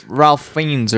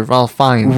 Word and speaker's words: Ralph Fiennes: No, like the Ralph Fiennes or Ralph Fiennes. – Ralph Fiennes. He's a pretentious fuck who thinks the L doesn't need to be pronounced.